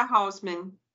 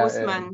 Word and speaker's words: Haussmann: Mas em francês a gente pronuncia Hausmann. É, Osman. É, Osman. Osman. Osman.